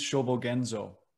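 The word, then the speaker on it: Shōbōgenzō: Dogen's Shobogenzo.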